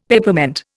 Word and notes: Peppermint: Indian

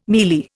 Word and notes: mielie: Indian

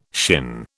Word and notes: shin: British English

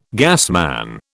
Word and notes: gasman: British English

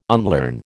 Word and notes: unlearn: American English

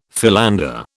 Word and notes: philander: British English

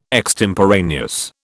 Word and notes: extemporaneous: British English

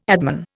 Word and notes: adman: Indian

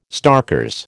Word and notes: starkers: American English

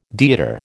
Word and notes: deodar: American English

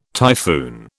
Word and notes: typhoon: British English

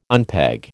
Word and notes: unpeg: American English

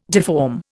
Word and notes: deform: Indian

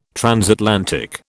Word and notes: transatlantic: British English

